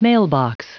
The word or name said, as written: mailbox